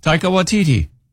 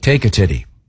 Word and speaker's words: Taika Waititi: And pronounce it again, Taika? – Taika Waititi. – Okay. I thought it was like Taika Titty.